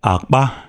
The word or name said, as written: Akpa